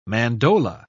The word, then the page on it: mandola: British English